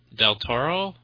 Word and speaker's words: Del Toro: Del Toro, isn't that how? – Yeah, that's how you pronounce it.